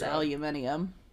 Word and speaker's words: aluminium: Well, aluminum, too. We say that weird. Everybody else says aluminium. – What? – Yeah. – Really? – What? – Aluminium. – What the fuck? – Which dude? Is just like it's spelled. If you look at how it's spelled.